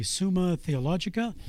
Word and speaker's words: Summa Theologica: And this work is, well, let's see if I can pronounce it correctly, Summa Theologica. Is that right? That's right, yes.